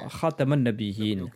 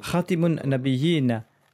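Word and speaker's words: Khataman Nabiyin (خاتم النبيين): Certes, ces deux prononciations sont possibles à dire Ali, mais je préfère la prononciation Khataman Nabiyin, car elle signifie le sceau des prophètes. Khatiman Nabiyin signifie celui qui met fin aux prophètes. Enseigne à mes enfants la prononciation Khataman Nabiyin avec la lettre Ta.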